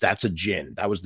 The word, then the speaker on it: Djinn: It's called The Djinn, and it looks like it's spelled Dudjin, but that's actually, the D is kind of silent, kind of like Django, like Djinn. That's how you do it.